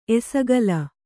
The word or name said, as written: ಎಸಗಲ